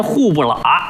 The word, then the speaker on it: 虎伯劳: 哎，老北京本地发音户不喇。哎，这写出来呀叫伯劳啊，老虎的虎，虎伯劳，念出来户不喇。